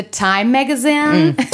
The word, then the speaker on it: Time Magazine: Deswegen hat auch das Time Magazine, die Casper-Matratze... – You have to pronounce it in English, please. Do it again. – Okay, it's the Time Magazine.